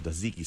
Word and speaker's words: tzatziki: They are absolutely delicious, and I serve them with a tzatziki sauce.